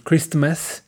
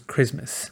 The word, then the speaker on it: Christmas: Porque después de out tenemos shopping y para poder pronunciar esa S con facilidad, nos comemos la T como nos comimos la T de Christmas. No dijimos Christmas, dijimos Christmas. ¿Ok?